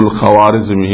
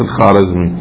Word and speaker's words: الخوارزمي: يقولوا الخوارزمي هي تنطق الخارزمي،